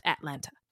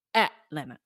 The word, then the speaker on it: Atlanta: By the way, it's not pronounced Atlanta. It's pronounced Atlanta. – Atlanta. Atlanta. – Don't say the T's.